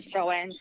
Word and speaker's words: Joanne: I have initially, my name is Joanne, spelled J-O-A-N-N-E, and lots of friends still call, just spell it J-O-A-N-N-E.